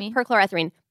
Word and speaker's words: perchloroethylene: Um, so, 70% of dry cleaners uh, use a chemical called, oh, I'm going to butcher this, perchloroethylene. That sounds right. Yeah, perchloroethylene. PERC for short.